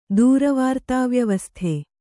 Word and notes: ದೂರವಾರ್ತಾವ್ಯವಸ್ಥೆ: kannada

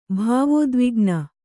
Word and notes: ಭಾವೋದ್ವಿಗ್ನೆ: bhāvōdvigne

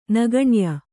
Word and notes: ನಗಣ್ಯ: kannada; nagaṇya

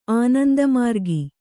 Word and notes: ಆನಂದಮಾರ್ಗ: ānandamārga

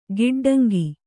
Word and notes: ಗಿಡ್ಡಂಗಿ: giḍḍaŋgi

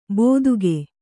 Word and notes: ಬೋದುಗೆ: bōduge

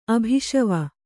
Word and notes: ಅಭಿಷವ: abhiṣava

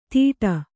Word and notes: ದಿಡ: Kannada

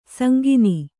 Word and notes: ಸಂಗಿನಿ: kannada

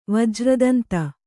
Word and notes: ವಜ್ರದಂತ: vajra danta

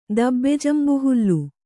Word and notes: ದಬ್ಬೆಜಂಬುಹುಲ್ಲು: Kannada

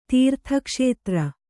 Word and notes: ತೀರ್ಥಕ್ಷೇತ್ರ: kannada

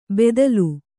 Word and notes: ಬೆದಲು: bedalu

